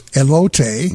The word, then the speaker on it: elote: So we are having a food fight tonight with, I believe the pronunciation is elote.